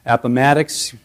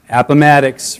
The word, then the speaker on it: Appomattox: Lee at the courthouse in, I don't know if I ever pronounced this word correctly, Appomattox, thank you. Appomattox, say it with me, Appomattox, Virginia. We can all get it right.